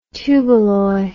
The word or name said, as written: tuballoy